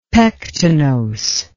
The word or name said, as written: pectinose